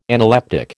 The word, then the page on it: analeptic: American English